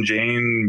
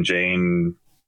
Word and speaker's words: Jayne: But at Benjamin Jane Music would be, I think, for Facebook and Instagram. Benjamin Jane, all streaming platforms.